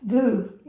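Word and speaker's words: Vous: V O V O U S. – Vu, yeah. Vu, yeah. – No, yes, yeah, I was pronouncing it wrong. Vu.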